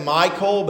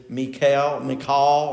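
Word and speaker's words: Michal: Now, some people pronounce that Michael, but I try to look up these words and they say the Hebrew pronunciation is Mikael or Mikael.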